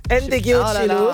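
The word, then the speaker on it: Ndegeocello: Autrice, compositrice, chanteuse américaine, Michelle Ndegocello. – Ndegocello.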